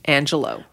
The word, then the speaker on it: Angelou: And we were told that the correct pronunciation is Angelou.